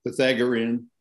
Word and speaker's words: Pythagorean: How do you pronounce Pythagorean theorem? – That's pretty. You're pretty close. Pythag- Pythagorean.